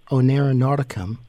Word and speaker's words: Oneironauticum: She's a dream explorer and writer and the founder of the Oneironauticum.